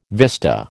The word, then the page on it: vista: American English